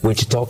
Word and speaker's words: Wichita: I was doing some meetings in Wichita, Kansas. – Why'd you say it like that? – Wichita, Kansas. You can't say it any other way.